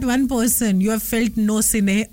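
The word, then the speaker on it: Gnossienne: And that feeling when यू feel for somebody is called नोसिने. जी, द स्पेलिंग इज GNOSSINNE. नो सिने, yes.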